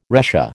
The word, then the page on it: retia: American English